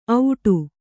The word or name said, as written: ಔಡು